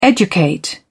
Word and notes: educate: British English

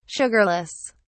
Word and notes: sugarless: British English

